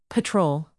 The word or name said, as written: patrol